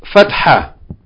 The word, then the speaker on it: fatha: F-A-T-H-A-H. The first H is the letter ha, as in jim, ha, and kha. The second H is ta marbuta. And that's why the second one, normally we're not pronouncing it, but it's pronounced like a soft H sound. Fatha, ha. And the H on the end is not fully pronounced, unless you put a vowel on it, okay?